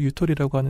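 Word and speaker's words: ゆとり: 이 여유를 일본 발음으로 유토리라고 하는데 여유가 있는 교육으로 교육의 방향을 크게 전환할 필요가 있다.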